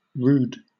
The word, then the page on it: rood: British English